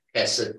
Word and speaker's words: hesed: The Jew who would read this book reads of God's, the Hebrew word for it is transliterated H-E-S-E-D. And you say it like you're clearing your throat.